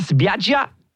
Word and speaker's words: spiaggia: Andiamo alla spiaggia.